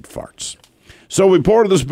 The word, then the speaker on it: Faartz: His name, and this is not a lie, by the way, his first name is spelled W-H-E-T, Dr. Wet Farts. Farts, F-A-A-R-T-Z. He's a real person. He's been interviewed many times. – W-H-E-T, first name? – W-H-E-T, Dr. Yeah.